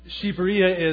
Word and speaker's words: Shqipëria: Church planting leadership training, as he mentioned, and Shqipëria is how it's pronounced and how it's said among Albanians. That's what they call their own country.